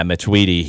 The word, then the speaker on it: Matuidi: Is it Matuidi? Is that how you pronounce his last name? – Yeah, Matuidi.